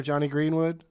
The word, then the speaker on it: Greenwood: Johnny Greenwood? Is that his name? Am I saying it right? – Yeah, anyway.